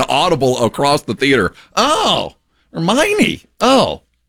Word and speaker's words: Hermione: I had never seen or heard of that name, Hermione, and it's spelled like Hermoine. So we were reading to our kids, like, we didn't say it right. And then the movie came out, I think the whole theater went, oh, Hermione.